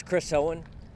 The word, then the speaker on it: Chris Owen: My name's Chris Owen, CHRISOWEN.